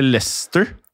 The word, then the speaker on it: Leicester: Um, ehm, Eller lester og så skrives det Leicester.